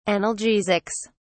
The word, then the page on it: analgesics: British English